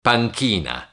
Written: panchina

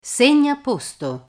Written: segnaposto

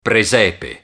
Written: presepe

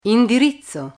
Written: indirizzo

